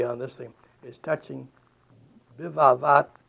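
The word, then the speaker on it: Bivavat (בבבת): And without the point, it's, we pronounce it then a V. So Bivavat.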